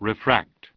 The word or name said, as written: Refracted